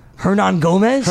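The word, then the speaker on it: Hernangómez: Hermanguez. – Hernan Gomez? – Hernan Gomez, yeah. That's how you pronounce his name? – Hernan Gomez, yeah. It's like Hernandez, but instead of the Andes at the end, it's Gomez at the end.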